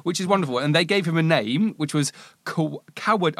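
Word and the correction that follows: Kwäday Dän Ts'ìnchi: I'm going to pronounce this wrong. Kwaday. Kwaday Dan Tsi'inchi, which means, very in my good.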